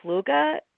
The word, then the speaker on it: Fluge: So his last name, the way Norwegians did it, really would have been Fluga, which is spelled F-L-U-G-E.